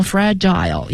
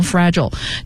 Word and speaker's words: fragile: 再来呢就是 fragile， F-R-I-G-I-L-E 啊，两个读音，可以读成 fragile，也可以读成 fragile，就是易损易碎的东西。